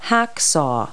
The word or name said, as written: hacksaw